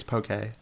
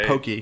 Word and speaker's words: poke: Every time, like, you know, poke is getting popular here in the States, this side of the States, so everyone pronounces it wrong, and I'm always like, it's poke. It's not pokey.